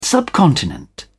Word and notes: subcontinent: British English

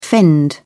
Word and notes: fend: British English

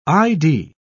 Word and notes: i.d.: British English

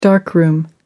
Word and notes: darkroom: American English

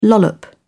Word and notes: lollop: British English

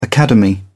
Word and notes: academy: British English

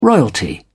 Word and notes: royalty: British English

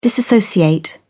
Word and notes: disassociate: British English

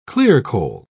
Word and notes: clearcole: British English

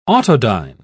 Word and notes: autodyne: British English

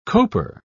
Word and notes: coper: British English

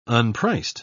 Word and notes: unpriced: British English

